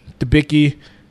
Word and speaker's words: Debicki: Jennifer Coolidge, Elizabeth, Debnicki. – Debicki.